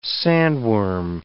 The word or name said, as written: sandworm